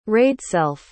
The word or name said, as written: raedself